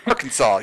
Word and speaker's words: Arkansas: She's like, if this is pronounced Kansas, why is this not pronounced Arkansas? America, explain! Explain! – Yeah, that's it. That apparently that has affected me. So, Arkansas,